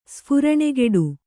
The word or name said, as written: ಸ್ಫುರಣೆಗೆಡು